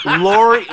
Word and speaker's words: Lori: So, I mean, if Laura Harvey wants to date Diddy and date Future and date... – Just change the A to an I.